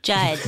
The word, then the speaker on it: Judd: Why are you still trying to find Jeb? – Judd. – Jed. – Jed. – Jed Weeby? – Judd. – Judd.